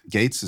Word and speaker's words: Gaetz: Gates, is that how he pronounces it? – Yes.